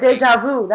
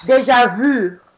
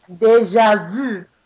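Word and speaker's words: déjà vu: You said deja vu. That's not how you pronounce it. – How do you say it? – Deja vu. – Say that again. – Deja vu.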